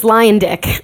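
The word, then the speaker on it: Luyendyk: It's Lion Dick. – Oh, right. Lion Dick. That's the same. – It's really easy to now do because it's Lion plus Dick.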